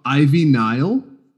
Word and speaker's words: Ivy Nile: The second match, we saw Amari Miller taking on Evie Niles. Saying her name right? I haven't watched a match. – Are you talking about Ivy Nile? – Yeah.